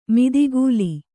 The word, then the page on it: ಮದಕಲ: mada kala